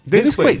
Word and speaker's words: Dennis Quaid: Dennis, Quaid.